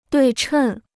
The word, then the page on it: 对称: duì chèn